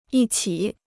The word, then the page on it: 一起: yī qǐ